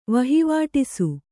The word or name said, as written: ವಹಿವಾಟಿಸು